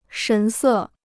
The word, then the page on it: 神色: shén sè